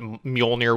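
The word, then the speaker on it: Mjölnir: So, the creature half, because we're seeing all these are creatures, and then like their god counterpart, you know, what Mjolnir would be to Thor, you know, kind of thing.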